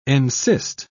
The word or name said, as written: encyst